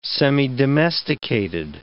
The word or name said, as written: semidomesticated